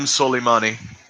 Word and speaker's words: Soleimani: So this whole this whole thing kicked off because uh, the, an Iranian general, Qasim Soleimani. Am I saying that right? Soleimani. – Qasim Soleimani.